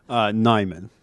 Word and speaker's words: Nyman: Also, how do you say your last name? Najimone? Seth? – Nyman. – Nyman. I wasn't even close. – Is it Nyman? – It is. Few Americans get it right.